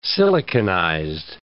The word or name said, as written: siliconized